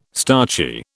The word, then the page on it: starchy: British English